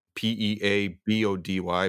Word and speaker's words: Peabody: P E A B O D Y, but in gotcha.